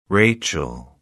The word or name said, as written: rachel